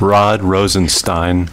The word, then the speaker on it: Rosenstein: Rod Rosenstein. – Oh, it's Stein! I said it's Steen over and over again in the montage.